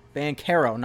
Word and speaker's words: Banchero: Paolo Banchero, not Banchero, not like churros, like the dessert.